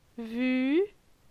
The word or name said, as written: Vu